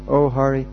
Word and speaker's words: O'Hare: O'Hare. It's spelled O-H-A-R-E. O, they pronounce it hare.